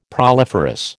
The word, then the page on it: proliferous: American English